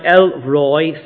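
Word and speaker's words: El Roi: In Hebrew, it's pronounced El Roy, simply the God who sees.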